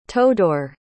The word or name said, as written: todor